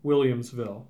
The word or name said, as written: Williamsville